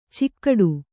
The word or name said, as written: ಚಿಕ್ಕಾಟ